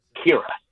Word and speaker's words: Ciara: The name is spelled C-I-A-R-A. It's my wife's name, and it's pronounced Kira.